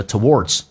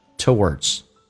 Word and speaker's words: towards: 第二个单词美式发音叫 towards，这个单词我知道英式发音我们一般都发成 towards，就是英式发音哈，或者你把它发成美式，这个美式的卷舌 towards，那这是很显然这是不太对的，因为英不英美不美的。那么美式发音一般是 o 加个 w 加个轻度卷舌 towards a little effort towards being polite.